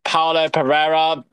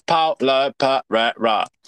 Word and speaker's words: Paolo Pereira: And just to put it out there, if you didn't listen, it is pronounced Paolo Pereira. The I is silent in the surname. So if you don't know how to pronounce it, the pronunciations is on my bio.